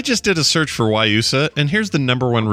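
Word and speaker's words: guayusa: It's pronounced Wayusa. G-U-I-G-U-A-Y-U-S-A. But it's pronounced Wayusa.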